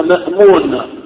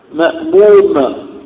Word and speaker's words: مأموم: مأمون بالنون وهذا خطأ. يعني الذي يصلي خلف الإمام نقول عنه ماذا؟ – مأموم وليس مأمون بالنون. إذن هذه الكلمة تنطق بالميم.